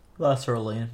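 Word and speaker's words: Lasaraleen: way for the Tarkheena Las- Lasarine. – Lasaraline, or Lasaraline? – La- yes, there's another syllable in there.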